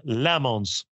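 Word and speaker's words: Lammons: Mitt då franska uttal som vissa lyssnare reagerar på. Men Amerika. nu då har jag övat in detta. Amerikanen Lamons. Snyggt.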